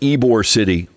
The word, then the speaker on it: Ybor City: So that's pronounced Ybor City.